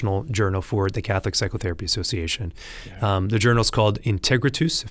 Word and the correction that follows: Integratus: if I'm saying it correctly, right? – Integratus. – Oh, geez, yeah. I got it wrong.